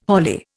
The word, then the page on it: holly: Indian